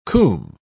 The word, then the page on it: coomb: British English